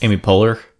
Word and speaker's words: Poehler: Amy Poehler.